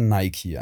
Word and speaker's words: Nike: Wo man auch zum Beispiel Nike, gibt es ja auch Nike, Nike. – Mhm. Ja, richtig ist ja Nike eigentlich.